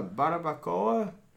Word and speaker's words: barbacoa: Have you guys ever heard of a barbacoa? – Oh, and some tater tots.